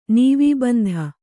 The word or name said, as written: ನೀವೀಬಂಧ